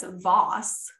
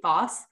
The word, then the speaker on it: Vose: So anyway, so actually for a very long time, there was a Rhode Island poultry dealer, Horace Voss, I believe is how you pronounce that, V-O-S-E, Voss.